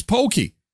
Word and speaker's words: poke: It's pronounced pokey? I did mispronounce. It's pokey. I call it poke because I want to be a snob.